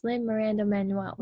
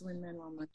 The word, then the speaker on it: Lin-Manuel: And then the last thing I was going to say about him, like, Lin-Manuel Miranda. I think I'm saying his name backwards. Lin-Manuel, right? – It's Lin-Manuel.